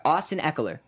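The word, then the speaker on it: Austin Ekeler: I'm gonna pronounce this guy's name wrong, but Austin Eckler. – Yeah, that's his name. That's how you say his name.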